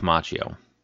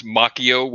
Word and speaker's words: Macchio: was pronouncing his name as Macchio, which is the correct pronunciation according to Italian pronunciation.